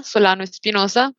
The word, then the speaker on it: Solano Espinosa: Bueno, pues mis coordenadas digitales me pueden encontrar por LinkedIn eh, con mi nombre completo, Juanita Solano Espinosa, todo con S.